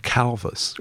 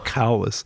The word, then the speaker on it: Calvus: named Calvus, or Calvus, I guess the Romans would have said. They pronounced their V's as W, Calvus.